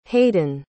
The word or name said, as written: hayden